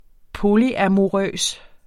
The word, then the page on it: polyamorøs: -amoˌʁœˀs; -ɑmoˌʁœˀs